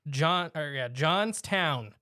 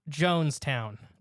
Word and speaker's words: Johnstown: And it's Johnstown, not Jonestown.